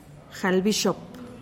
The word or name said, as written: jalbixop